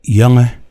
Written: Yenge

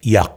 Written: Yak

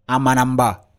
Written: AMANAMBA

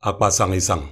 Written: akpasangisang

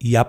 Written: Yap

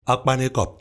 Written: akpanaekok

